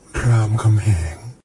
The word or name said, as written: Ram Khamheang